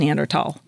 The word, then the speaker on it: Neanderthal: But it's a German word. And it's always been pronounced Neanderthal.